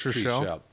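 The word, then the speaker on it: Trichel: It's T-R-I-C-H-E-L. I think I called it Treshell. It's Treshell. Yeah.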